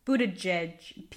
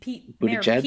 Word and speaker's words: Buttigieg: come forward with an allegation about Pete Buttigieg. Is that how it's said? – Buttigieg. Pete Buttigieg.